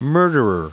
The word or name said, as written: murderer